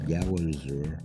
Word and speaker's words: Galizur: and it's actually also spelled and pronounced Galizur, G A L I Z U R.